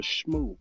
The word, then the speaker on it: Shmoo: Also, there's a Mark Gonzalez collab with Adidas Superstar, the Shamu. The Shmoo. I'm sorry. Shmoo. Shmoo. Shmoo. I said that wrong.